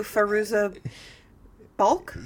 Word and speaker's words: Fairuza Balk: I believe you're referring to Fairuza Balk? – Yes.